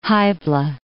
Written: Hybla

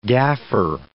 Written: gaffer

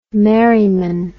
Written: merryman

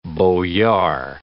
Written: boyard

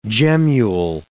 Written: gemmule

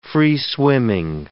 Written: free-swimming

